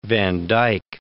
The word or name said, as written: Vandyke